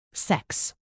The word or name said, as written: sex